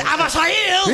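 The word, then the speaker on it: Avisaíl: That's right, it's pronounced Avasail! – He is correct.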